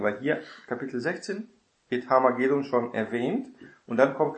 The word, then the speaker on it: Harmageddon: Es geht um Harmageddon, ne? Die Betonung auf der letzten Silbe. Wir merken schon, dass hier auf Deutsch, ne, man aufrutscht auf diese Aussprache, Harmageddon oder so, ne?